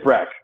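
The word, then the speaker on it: Brach: Best pronunciation podcast of all time. – I think it's Brack. I'm sorry. It's Brad Brack, who was an All-Star last year.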